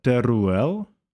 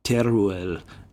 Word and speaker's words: Teruel: how do you pronounce that word? Telur or something? – Was that Teruel? – Yeah, Teruel.